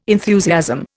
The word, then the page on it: enthusiasm: Indian